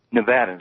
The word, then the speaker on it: Nevada: Tell me how to say it. You're on the air with Senator Sanders. – Nevada is right.